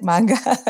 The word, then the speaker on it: manga: Is that how you pronounce it? Manga? – Yeah, manga.